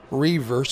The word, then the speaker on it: Roever: Or if they want to go directly to my name, Dave, D-A-V-E, Reaver, spelled R-O-E-V-E-R. Everybody spells Rover. It's not Rover. It's R-O-E-V-E-R, and the O is silent, .org.